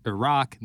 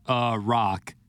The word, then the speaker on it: Iraq: They're saying it's Iraq, not a rock.